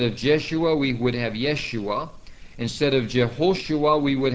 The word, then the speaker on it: Yeshua: And so we can eliminate two of these names right now by realizing that Jeshua and Yeshua are really the same name, except that Jeshua has the Latin J, which is would have been pronounced like a Y or Yeshua.